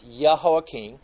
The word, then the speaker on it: Jehoiakim: We're going to find out, first off, Jehoiakim, and remember, in Hebrew, J's are pronounced with a Y sound, so you'll see it in your Bible as J, but it's Jehoiakim.